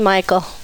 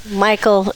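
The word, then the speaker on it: Michale: Callie, Michael. Michael is for Michelle Lee. My dad liked Michelle Lee, but my mom liked the name Michael. So it was spelled on my birth certificate, Michelle, but pronounced Michael.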